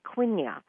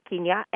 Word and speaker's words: quinoa: I would want to pronounce it quinoa, but it's quinoa,